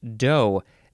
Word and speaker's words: do: Now, this one actually is dough. That's how it's pronounced, dough.